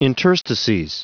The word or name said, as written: interstices